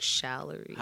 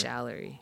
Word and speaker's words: Charlery: It's A R L E R Y, and it's pronounced like Shallery.